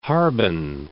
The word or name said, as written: Harbin